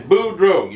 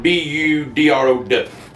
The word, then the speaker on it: Boudreaux: I said, my name is Boudreaux. You spell that Boudreaux, but you can pronounce it Boudrow.